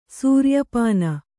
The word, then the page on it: ಸೂರ್ಯಪಾನ: sūrya pāna